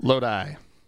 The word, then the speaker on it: Lodi: Lodi.